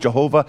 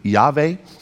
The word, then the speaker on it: Yahweh: I said, you use the word Jehovah. I said, that's really not right. It's really Yahweh.